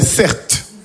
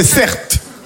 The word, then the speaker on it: Certe: Je m'appelle Certes. Mon prénom c'est Certes. Ça s'écrit C-E-R-T-E.